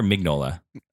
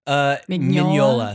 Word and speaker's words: Mignola: Mignola's. Is it Mignola or Mignola? – Mignola. – Mignola. So the G disappears.